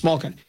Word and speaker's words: Malkin: Malkin.